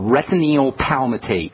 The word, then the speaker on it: retinyl palmitate: It's called retinyl palmitate.